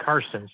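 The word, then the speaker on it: Czarson: Well, it's spelled with a Z, though. So it's C-Z-A-R-S-O-N. But okay.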